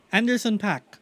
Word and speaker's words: Anderson .Paak: Anderson Paak.